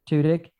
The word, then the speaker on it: Tudyk: Is that how you say it? Tudyk. – Tudyk. – Yep.